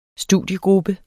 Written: STUDIEGRUPPE

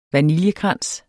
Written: vaniljekrans

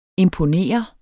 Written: imponere

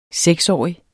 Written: seksårig

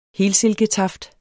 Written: helsilke

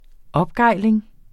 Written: opgejling

